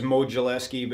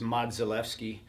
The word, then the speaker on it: Modzelewski: Well, everybody says Modzelewski, but in Polish it's Modzelewski.